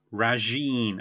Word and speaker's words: Rajiin: Well, our next episode, and I'm not sure how to pronounce this, but it's Rajine. – All right. – Rajine, yeah. R-A-J-I-I-N.